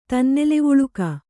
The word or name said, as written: ತನ್ನೆಲೆವುಳುಕ